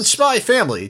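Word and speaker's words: Spy x Family: He said, in Spy Family, do you pronounce the X? And then it cuts to him and he just goes, no!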